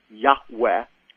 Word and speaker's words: Yahweh: And then the Lord pronounced His name over Moses, which most people believe is pronounced a breathy Yahweh, which comes from those four Hebrew letters, Yod-Heh-Vav-Heh.